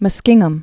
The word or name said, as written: Muskingum River